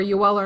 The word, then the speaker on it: Joel: And Jake Evans and Joel Armia are both, or Yoel Armia, to pronounce it correctly, are both that kind of guy.